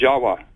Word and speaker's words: Jawa: The word in Java, the island of Java, you know, is pronounced Jawa. Actually, Indonesians will pronounce it Jawa, not Java like we do.